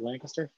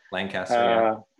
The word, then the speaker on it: Lancaster: Am I saying that right? Lancaster? – Yeah, Lancaster.